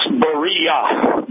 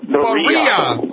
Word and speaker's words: Berea: No, it's Berea. – Berea. Oh, I'll remember that because just like my wife, Maria. I'll just say it rhymes with Maria.